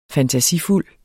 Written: fantasifuld

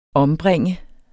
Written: ombringe

